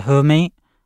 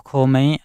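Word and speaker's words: Khoomei: E fanno appunto questa musica chiamata Homey, o Homey, a volte la K non si pronuncia,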